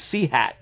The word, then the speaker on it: CHAT: And so you are on – is it pronounced Chat? I always look at it as C-hat, but is that wrong? – No, it's Chat, because we live in Medicine. It's a Chat television.